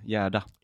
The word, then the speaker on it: Gerda: G-E-R. – Yeah, it's a, like almost silent R. Gerda.